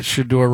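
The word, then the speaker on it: Shedeur: Is it weird that Shador rhymes with Masseur? – Yeah.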